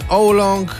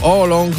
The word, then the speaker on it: oolong: I herbatę białą i puert, mamy żółtą, mamy oolong, czyli niebieską tak zwaną, albo oolong, w zależności jak tam chcecie wymawiać.